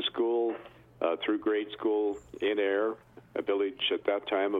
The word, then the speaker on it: Ayr: The village I came from was spelled A-Y-R and pronounced Air.